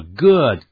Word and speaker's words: good: 这个 good 跟 bad， B-A-D， bad 就是坏的意思，这个 good 的发音呢跟这个 look 是元音是完全一样的， that's very good，我们念一下 good look，到时候想起书也是一样的发音， book， B-O-O-K， book，这个 book，我现在会念，但也不要念成呢 book，更不要念成 book。